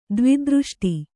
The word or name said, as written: ದ್ವಿದೃಷ್ಟಿ